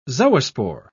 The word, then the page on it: zoospore: British English